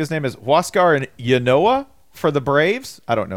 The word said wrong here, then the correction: Inoa: Huascar Inoa, I think is how you say it.